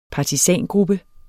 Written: partisangruppe

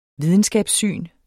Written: videnskabssyn

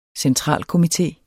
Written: centralkomité

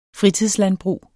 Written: fritidslandbrug

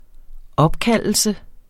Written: opkaldelse

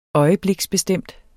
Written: øjebliksbestemt

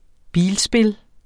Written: bilspil

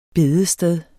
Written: bedested

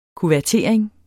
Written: kuvertering

